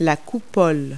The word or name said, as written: cupola